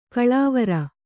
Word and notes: ಗಲವಾರ: kannada

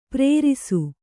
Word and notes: ಪ್ರೇರಿಸು: Kannada

